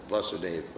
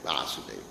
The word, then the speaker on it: Vasudev: Remember, I'm pronouncing that word, Vasudev, Vasudev. Remember that. Listen to the pronunciation.